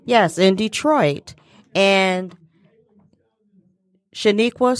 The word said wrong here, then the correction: Shaquana: Suggs came down to I pronounced it wrong. Shaquana. I don't know why I said Shaniqua. Shaquana